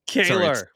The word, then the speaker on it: Keller: Thanks for using the not Canadian pronunciation of my last name. It's Kaler.